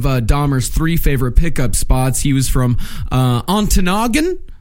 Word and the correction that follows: Ontonagon: Yeah, I think it's pronounced Antonagon. – That sounds right. Yeah, Antonagon. Yeah.